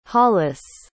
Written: hollis

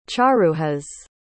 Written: charuhas

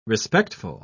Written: respectful

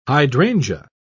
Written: hydrangea

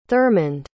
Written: thurmond

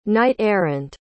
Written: knight-errant

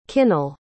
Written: kinnel

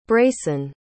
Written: brayson

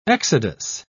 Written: exodus

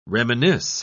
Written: reminisce

